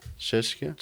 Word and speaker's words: Czechia: Czechia.